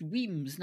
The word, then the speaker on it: Wemyss: It looks like Wemyss, uh-huh. But it's just Weems, nice and straight.